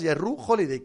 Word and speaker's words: Jrue Holiday: Me gusta cuando pronuncias Jrue Holiday. ¿Te importaría volver a hacerlo? Gracias, Jrue Holiday. Que sepas que yo no tenía ni idea de cómo se pronunciaba.